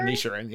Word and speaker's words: Inisherin: The Banshees of Inisherin or whatever. – Inisherin, yeah.